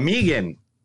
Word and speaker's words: M3GAN: It's Megan, but I'm calling it M3GAN because that's what it says.